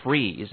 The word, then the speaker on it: Fries: McCall, I mean, you were really Bill Fries, and that's spelled F-R-I-E-S. – Yeah, well, it's not like French fries, but it's not. You know, it's a German name, Fries.